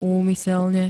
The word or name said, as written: úmyselne